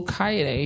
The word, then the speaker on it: Olukayode: And follow me on Facebook at Wale Olukayede, if you can't spell that. W-A-L-E. last name is O-L-U-K-A-Y-O-D-E.